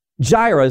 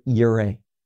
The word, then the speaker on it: Yireh: Now, Jireh is not the right way to pronounce it. It is the word Yireh.